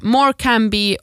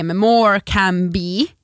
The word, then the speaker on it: Morecambe: Och Morecambe säger man tydligen Morecam. Alltså M-O-O-R-K-A-M. Morecambe.